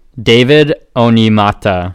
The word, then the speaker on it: David Onyemata: David Oyelamada. That's a mic drop right there. – We'll see. – David Onyemata. – The correct pronunciation is David Onyemata.